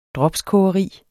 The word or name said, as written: dropskogeri